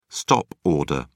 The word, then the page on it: stop order: British English